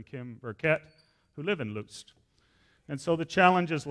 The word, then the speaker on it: Lutsk: So now we get on to Lutz. That's Lutz at the top. So here's the challenges. That's how you say it in English. That's how you see it in English. But that's how you spell it.